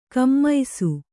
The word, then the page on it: ಕುಮ್ಮಿಸು: kummisu; kannada